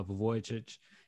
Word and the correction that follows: Vucevic: is oh I probably butchered his name. Nick, it's Again. – Nikola Vucevic. – I had I, it came to me as soon as I like disconnected. I was like, it's Nikola Vucevic.